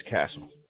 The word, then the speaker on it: Castle: Last name is Castle. – Can you spell that for me? – C-A-S-T-L-E.